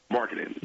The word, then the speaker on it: Markkanen: Markkanen.